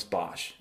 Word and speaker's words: Bosch: He signed a number of his paintings as Bosch, pronounced Bos in Dutch. The name derives from his birthplace, S-Hertogenbosch, which is commonly called Den Bosch or Den Bosch if Bosch is Boss.